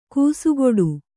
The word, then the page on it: ಕೂಸುಗೊಡು: kūsugoḍu